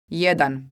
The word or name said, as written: jedan